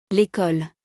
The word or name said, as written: L'Ecole